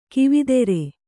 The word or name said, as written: ಕವಿತರು